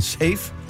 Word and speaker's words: Saif: Safe. – Safe. – Yes. – Safe. – S-A-I-F, korrekt? – S-A-I-F, men udtales der.